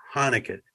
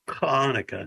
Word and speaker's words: Hanukkah: And I have to say this, it starts with an H, Hanukkah. That's the American way. The Jewish way, it's hard for me to pronounce, it's Hanukkah. – It's guttural. – It's with the C-H, Hanukkah.